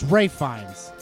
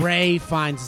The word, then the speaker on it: Ralph Fiennes: It's Ray Fiennes. – Oh, or wait, what? Oh, it's Ralph. It's Ralph. – Ray Fiennes is how you say his name.